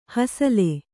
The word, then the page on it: ಹಸಲೆ: hasale